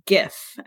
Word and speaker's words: GIF: It is pronounced GIF, and that's because it's an acronym. And the first word in that acronym is graphics, which you say GIF. So I say GIF.